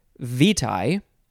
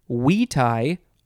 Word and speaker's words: vitae: And then the second word can also be pronounced vitae or vitae. So many ways. Vitae, vitae, vitae, curriculum, vitae, I think is the way that I want to say it. Oh, by the way, that second word is spelled V-I-T-A-E.